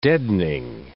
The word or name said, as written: deadening